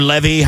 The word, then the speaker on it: Levy: Of course, son of his very, very famous dad from the 80s and 90s, Eugene Levy, and or Levy, however you want to pronounce it. I say Levy. My wife says Levy.